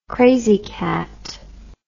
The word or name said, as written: krazy kat